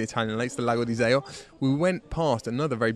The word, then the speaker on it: Iseo: Riva del Garda to Iseo. Is that a correct pronunciation? Iseo. I said it wrong earlier then.